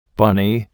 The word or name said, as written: bunny